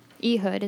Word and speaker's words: Ehud: And this is the story about Ehud.